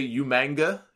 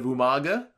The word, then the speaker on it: Umaga: Like, kind of the way he used to say Umanga instead of Umaga, because he just butchered this name when he said it.